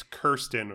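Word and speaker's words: Kyrsten: Although I am going to say you definitely get the point, but I do want to hear you pronounce her first name one more time, please. – Is it Kirsten? – It's K-Y-R. – Oh, shit. I've been saying Kristen this whole time. – Yeah, it's Kirsten or Kirsten.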